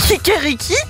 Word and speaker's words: Kikeriki: En allemand, c'est kirekiki. Non, kirekriki. Waouh, ouais, bon, voilà. – J'aime bien Lorenza, les infos à moitié. Bon, bah voilà, le truc, j'arrive pas à le prononcer. – Kikeriki.